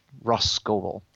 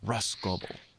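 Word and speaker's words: Rhosgobel: So according to Encyclopedia of Arda, from our good friend Mark Fisher, it's the pronunciation is literally just Roskoble. So Roskoble. – Yeah, he puts R H O apostrophe S G O B L B E L as the pronunciation.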